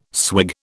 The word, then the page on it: swig: British English